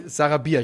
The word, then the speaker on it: Sarabia: Ähm, und ich glaube, dass, äh, wie spricht man aus? Sa Sar Sarabia? Sarabia, ich wollte Sarabi sagen.